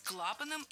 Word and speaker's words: клапанам: Клапанам и поршням. Оба слова произнесены неправильно. Ошибка однотипная. Должно быть клапаном и поршнем, так как перед нами слова с неподвижным ударением на основе. И в них ударение всегда падает на первый слог.